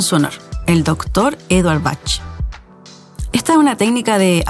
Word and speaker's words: Bach: Lo común que oímos siempre decir, ¿cierto?, es flores de Bach. Bueno, te voy a decir que la correcta forma de pronunciarlas es flores de Bach con CH como de chocolate. Bach es la forma de pronunciar este apellido en Alemania, como por ejemplo el músico Juan Sebastián Bach.